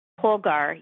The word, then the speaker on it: Polgar: Susan Polgar? – Polgar, yes.